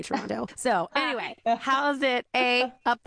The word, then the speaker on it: Toronto: But you have to pronounce it like Toronto. There's no T at the end.